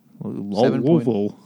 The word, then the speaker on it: Louisville: Louisville. – You got to say it like you have a mouthful of goat testes.